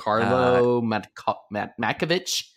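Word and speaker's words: Karlo Matković: Carlo Matkovic. – Yeah. – I think I actually didn't butcher that one, guys.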